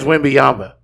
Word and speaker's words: Wembanyama: We lost it with Wembanyama. Wembanyama. – You said his name almost accurately. – I did. – That's the first time I've ever heard anybody. – I believe it is Wembanyama.